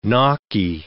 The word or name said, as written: gnocchi